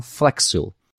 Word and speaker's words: Flexcil: Ela se escreve Flex, F-L-E-X, e SIL, C-I-L. Então, Flexil.